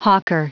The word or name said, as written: hawker